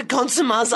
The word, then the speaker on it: consummate: We should not have such a consumé artist as Penny Grouch. – It's pronounced consumans. – Consumans. We should not have such a consummate artist as Penny Grouch.